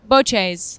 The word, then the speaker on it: bocce: Bocce ball, actually. Just so bocce is how we say it in America. But Boches, how you say it in Italy.